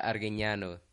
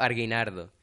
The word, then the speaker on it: Arguiñano: Ya eh, eso fue que Iván tuvo un problema para pronunciar arguiñano. Entonces dijo arguinardo.